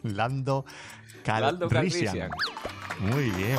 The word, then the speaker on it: Calrissian: Y la C, Lando Calrissian.